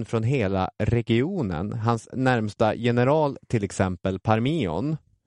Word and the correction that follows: Parmenion: Satte jag uttalet? – Ja, det är något åt det hållet. Parmenion.